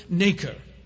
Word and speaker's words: nacre: When a foreign object invades an oyster, the animal coats the irritant with a substance called nacre. It's acres with an N in front of it. That's how you spell it.